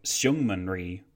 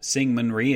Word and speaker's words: Syngman Rhee: Moving away from China, the South Korean leader, Syngman Rhee, was also a cause for some confusion, because depending on whom you ask, the name is pronounced literally or more like Syngman Rhee. Since it seems that Syngman Rhee is perfectly acceptable, and you'll be happy to know that I asked a listener who is living in Korea what the best way to pronounce it is, and he told me Syngman Rhee is fine and that Syngman Rhee is completely not fine, I've decided to go with Syngman Rhee, and at least you know I actually researched things this time and didn't just make it up on the spot.